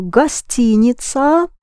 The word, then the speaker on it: гостиница: A hotel, the word for hotel is гостиница. You spell it гостиница, гостиница, because гость, the first part of it, is a guest. Yes? So гостиница rhymes with улица and нравится.